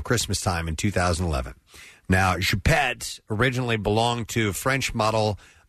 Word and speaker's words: Choupette: He had a cat named, and I tell me if this is correct, Nick, you're good with French pronunciations, Choupette? – Yes. – C-H-O-U-P-E-T-T-E? – Yeah, I think it's a term of affection.